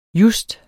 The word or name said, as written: just